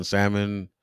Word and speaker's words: salmon: Pronounces the L in salmon.